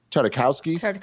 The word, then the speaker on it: Tartakovsky: And Gendy Tartakovsky, how do you say his name? Tartakovsky? – I don't know. – Yeah, you nailed it. Tartakovsky.